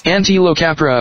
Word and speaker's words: Antilocapra: Antilocapra americana. – Wait. Okay, there's also this. – Antilocapra. – And this. – Antilocapra.